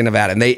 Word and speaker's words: Nevada: You got to say Nevada.